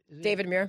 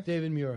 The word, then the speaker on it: David Muir: Bill Moore. – David Muir. – David Muir. Honey, that would be better. If I actually got his name right.